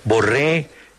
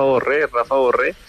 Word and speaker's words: Borré: porque pues también para ellos es bastante difícil pronunciar el Borré por la doble R que acá en Alemania no se pronuncia tanto.